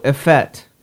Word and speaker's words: effete: E-F-F-E-T-E. Oops, there's too many. – It's got a long E. – Right. – Effete. – No, a fet. It's pronounced a fet. – But that's the long E.